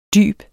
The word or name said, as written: dyb